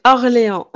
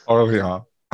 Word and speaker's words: Orléans: yeah, we have New Orleans, which we pronounce it wrong and pronounce all the French stuff wrong. – C'est Orléans. – Orléans.